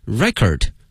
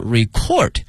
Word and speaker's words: record: 那么这个词的发音啊，同学们要小心了，作为名词呢它的念法是 record，如果作为动词呢变成 record during the Han and the Jin Dynasty，汉代和近代哈两个朝代呢。